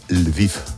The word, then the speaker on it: Lviv: En ucraniano se dice Lviv, pues es Leópolis.